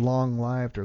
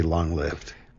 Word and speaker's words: long-lived: And expressions like long-lived, producing the last part to rhyme with dived, is more traditional, but rhyming it with sieved is so common that it's now widely acceptable.